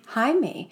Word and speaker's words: Jaime: Jaime. – Mm-hmm. And then, was it Joe or Gordon's like, what did you call him? And he's like, that's how you pronounce it in Latin America.